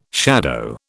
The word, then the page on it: shadow: British English